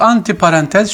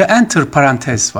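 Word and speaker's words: entre parenthèses: Aslında böyle bir tabir yok. Fransızca enter parantez var. Parantez içinde demek. Laf arasında anlamların hiç olmazsa antir parantez şeklinde telaffuz ediliyor ki bu yanlış bir deyim efendim.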